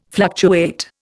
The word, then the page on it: fluctuate: Indian